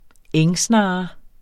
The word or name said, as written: engsnarre